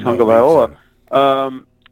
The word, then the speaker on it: Tagovailoa: and I thought Tua Tagovailoa, I mispronounced it already, Tagovailoa, had his helmet on and was about to come in the game.